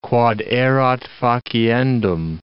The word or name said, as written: quod erat faciendum